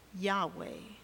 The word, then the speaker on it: Yahweh: In Hebrew, we pronounce it Yahweh.